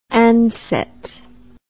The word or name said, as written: ansett